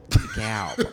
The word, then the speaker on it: DeKalb: Oh, DeKalb? Because yes. That's the way it's pronounced. – I know it is, but it's very accurate. – I don't know that it's pronounced like that. Like, it's Dutch.